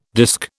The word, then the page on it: disk: British English